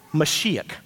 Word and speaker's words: Mashiach: I actually got corrected in the previous service. Mashiach, I'm saying it right now.